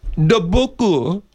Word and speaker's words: Dubuque: The correct pronunciation is Dubuque or Dubuque.